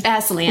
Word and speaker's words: Aslan: Aslan.